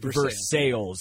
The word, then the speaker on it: Versailles: And yes, it's pronounced Versailles.